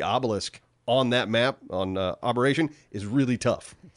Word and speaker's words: Aberration: You can transfer items from abortion. How do you say it? – Aberration.